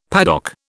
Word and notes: paddock: British English

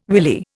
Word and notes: willy: Indian

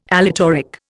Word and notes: aleatoric: Indian

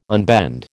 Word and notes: unbend: American English

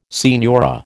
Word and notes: signora: American English

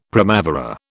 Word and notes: primavera: British English

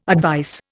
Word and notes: advice: Indian

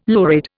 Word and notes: lurid: Indian

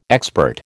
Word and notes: expert: American English

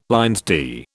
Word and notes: LL D: British English

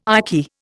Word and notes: icky: Indian

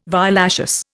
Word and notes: violaceous: Indian

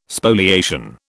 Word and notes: Spoliation: British English

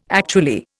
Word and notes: actually: Indian

